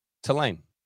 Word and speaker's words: Tulane: Tulane.